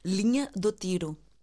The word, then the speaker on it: Linha do Tiro: Wie wird sie ausgesprochen? Linha do Tiro.